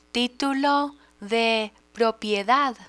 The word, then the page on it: titulo de propiedad: spanish